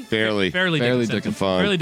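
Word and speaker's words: Fairleigh Dickinson: I'm going during the Farley Dickinson game. Did I say that correctly? Farley? – Fairly Dickinson game.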